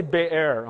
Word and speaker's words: Beer: From there, they went to I, I hate to pronounce it beer because somebody might get the wrong impression, but they went to, let's say Bay Air, okay? That sounds better.